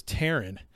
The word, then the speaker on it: Tehran: But he can never pronounce Tehran. He always says Tehran or Tehran or Tehran, but it's Tehran.